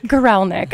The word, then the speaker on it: Guralnik: No, Guralnik. – Guralnik. I am terrible with names if I don't get a phonetic breakdown.